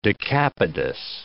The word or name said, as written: decapodous